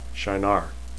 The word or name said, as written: shienar